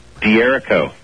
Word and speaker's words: D'Errico: D'Errico.